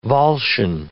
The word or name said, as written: Volscian